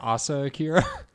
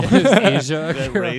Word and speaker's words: Asa Akira: Asia Akira. – Wait, did I say Asia? – Yeah. – Oh, I don't know. It's ASA. I don't know.